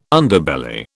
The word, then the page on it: Underbelly: British English